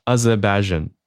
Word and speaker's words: Azerbaijan: 好，Armenia、 Armenia 跟 A Azerbaijan 哦，好好异国的声音哦。